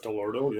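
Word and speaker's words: Dell'Orto: And it used 32 millimeter Delorto carburetors, right. If I'm saying that right. – Yep, Delorto, yep. It's Italian.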